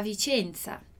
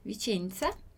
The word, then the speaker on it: Vicenza: Mas eu não digo Vicenza, eu digo Vicenza, Vicenza, com o nosso som aqui do TS, ok?